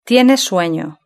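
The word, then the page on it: tiene sueno: Spanish (Spain)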